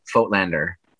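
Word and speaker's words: Voigtländer: And I'm happy to hear you pronounce it like I do, with an F, Foatlander.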